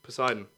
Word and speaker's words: Poseidon: Poseidon? – Poseidon.